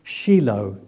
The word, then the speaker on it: Shiloh: Shiloh, yeah, which is actually pronounced Shiloh, it says according to the notes that I read. I've always called it Shiloh, but we'll call it Shiloh because if that's how it's supposed to be done, that's fine.